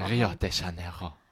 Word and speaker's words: Rio de Janeiro: Wie wird es denn brasilianisch-portugiesisch ausgesprochen? – Rio de Janeiro. – Rio de Janeiro. So ungefähr.